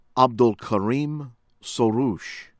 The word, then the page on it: Abdolkarim Soroush: American English